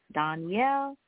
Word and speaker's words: Donyale: My name is spelled D-O-N-Y-A-L-E, Danielle, and Renee, R-E-N-E-E.